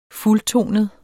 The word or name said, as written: fuldtonet